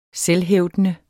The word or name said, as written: selvhævdende